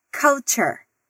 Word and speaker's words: culture: A gente escreve culture, mas a gente pronuncia culture. Repete aí. Culture. Culture. Isso aí. A primeira sílaba tem o U com o som mais aberto. E a segunda sílaba dessa palavra também é interessante, porque apesar de escrevermos ture, pronunciamos "ture".